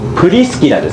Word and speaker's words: プリスキラ: そしてその後で、残っていたのがアクラとプリス、アクラと、いつも発音ちゃんとできないですね、プリスキラですね。